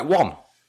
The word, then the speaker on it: Wan: I always say it like Wan.